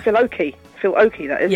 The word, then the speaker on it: Oakey: Oh, is it not with an L? Is it not Oakley? – Oh, it's Phil Oakey, Human League.